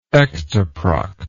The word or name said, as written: ectoproct